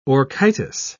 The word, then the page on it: orchitis: British English